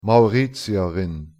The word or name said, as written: mauritierin